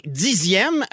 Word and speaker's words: dixième: On écrit 10 avec un X alors qu'on prononce S. 10. – Oui. – Mais on écrit dizaine avec un Z. – Oui. – Et dixième avec un X qu'on prononce comme un Z.